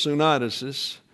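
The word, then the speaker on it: syneidesis: Actually, it's pronounced sunidesis, but you have the tendency to want to accent the eta, sunidesis. But it's sunidesis, which means to know with a norm or a standard.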